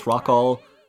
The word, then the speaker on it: Rockall: Where they basically pronounce Rockall like f-all. Because that's what's there.